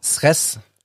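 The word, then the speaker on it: Cres: Äh, deswegen, also hat ähm, äh, eine Fotoserie gemacht und verrät mir, weil ich Äh, nur Deutsch halbwegs gut kann, wo die gemacht worden sind, damit ich es richtig ausspreche. In? – Sres. Auf Sres in Kroatien.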